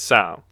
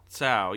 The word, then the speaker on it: Tso: I think it's Tso. – Tso, yeah. – It's T-S or S-T-O. – Yes. – I think it's T-S-O.